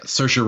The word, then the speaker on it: Saoirse: Saoirse Ronan for Lady Bird.